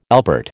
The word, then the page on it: albert: American English